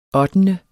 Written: ottende

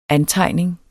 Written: antegning

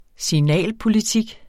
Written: signalpolitik

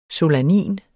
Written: solanin

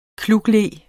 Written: klukle

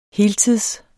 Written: heltids

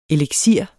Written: eliksir

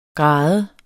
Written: grade

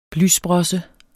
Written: blysprosse

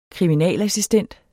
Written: kriminalassistent

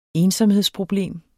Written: ensomhedsproblem

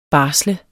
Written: barsle

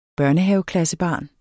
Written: børnehaveklassebarn

